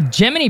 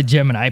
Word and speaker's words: Gemini: The Gemini program, or the Gemini program, people do pronounce it differently, I noticed, and that is fine. We're not... – That includes me and you. – Yeah, exactly. Includes us. There's no particular club of which is right or which is wrong, so we'll just stick to that.